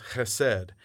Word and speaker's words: chesed: One is a Hebrew word, and it's pronounced chesed, and it's a love that is steadfast, a love that is never-ending, a love that is enduring.